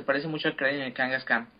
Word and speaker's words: Kangaskhan: Cubone y Kangaskhan.